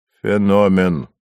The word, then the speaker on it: феномен: «Феномен». – «Феномен», — поправил Никодима Горький, поставив ударение на второй слог.